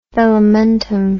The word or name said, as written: velamentum